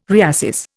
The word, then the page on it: reassess: Indian